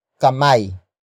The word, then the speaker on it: kamay: And that word is kamay. Kamay. Kamay. Kamay is spelled as K-A-M-A-Y.